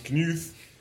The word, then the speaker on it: Knud: Thor's brother, it's pronounced Knuth, early 20s, rugged but not as hard as Thor, steps in from the blizzard, shivering and covered in snow.